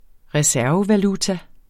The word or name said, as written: reservevaluta